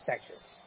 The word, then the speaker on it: Tejas: I don't know what Tejas is. It's pronounced Texas.